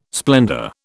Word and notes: splendour: British English

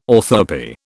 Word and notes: orthoepy: British English

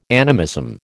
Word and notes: animism: American English

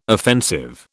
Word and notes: offensive: British English

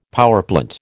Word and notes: powerplant: American English

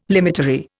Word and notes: limitary: Indian

